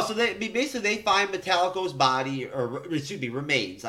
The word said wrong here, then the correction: Metallo: I apologize if that's the right way to say it. – Metallo. – Metallo. Why? How did I say it? – Metallico.